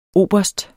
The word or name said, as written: oberst